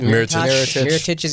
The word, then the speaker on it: Mirotić: Bucks getting Mirotić or whatever how you say it. – Mirotić is interesting.